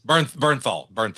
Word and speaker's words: Bernthal: Frank John Bernthal, is that his name? Is that how you say it? – Yeah, Bernthal.